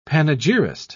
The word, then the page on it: panegyrist: British English